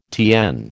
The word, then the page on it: TN: American English